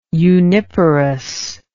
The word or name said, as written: uniparous